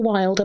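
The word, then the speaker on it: Wyld: No, but if you Google Ruth Roper Wild, and my surname is spelt Whiskey Yankee Lima Delta Echo, so it's a slightly unusual way of spelling Wild.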